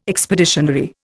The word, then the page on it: expeditionary: Indian